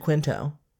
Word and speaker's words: Quinto: Mr. Quinto.